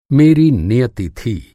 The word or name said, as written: नियति